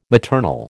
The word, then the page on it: maternal: American English